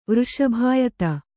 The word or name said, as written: ವೃಷಭಾಯತ